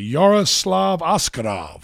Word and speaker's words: Yaroslav Askarov: Yeah, it should be Yaroslav Askarov.